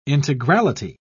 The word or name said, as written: integrality